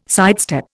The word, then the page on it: sidestep: Indian